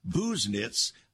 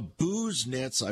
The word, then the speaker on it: Busenitz: Lowell Busenitz calling us Busenitz. I got that correct now. Busenitz. I've got the correct pronunciation.